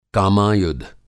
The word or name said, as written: कामायुध